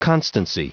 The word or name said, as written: constancy